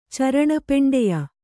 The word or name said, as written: ಚರಣಪೆಂಡೆಯ